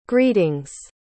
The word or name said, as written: greetings